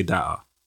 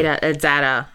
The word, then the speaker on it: data: I hate when Americans say data. Like the data show. – Data. – Yeah, like it's definitely data. – It's data. Yeah.